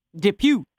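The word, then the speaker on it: Dupieux: Rubber and Deerskin filmmaker, I'm going to butcher this, Quentin Depew. – There you go.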